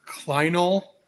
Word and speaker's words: clinal: But we're going to bring him on and talk to him about some things, you know, like, I never pronounce this right, Steve, help me out. Clinal. – That's right.